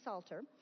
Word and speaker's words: Psalter: Some of you may see the word Psalter when you're referring to the whole book of Psalms, and that is another word that starts with that silent P, Psalter.